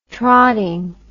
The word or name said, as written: trotting